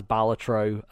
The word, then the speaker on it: Balatro: I should say Balatro. That is the company line. That is the way it's supposed to be said. So must be pronounced as Balatro.